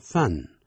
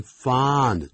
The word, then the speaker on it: fond: 但念这个字不要念成 fun, 要念 fond, 嘴巴大声一点。如果 I'm fond, 没有这样的一个字啊、哦、那是 f-u-n 才有这个字 ,fun, 代表乐趣。